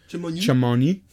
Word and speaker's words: Chamonix: I highly doubt they... pronounce the X in that Chimonio? Being French. Chamonix? Yeah. – Chimonio? – Yeah, probably. Chamonix? Yeah. But,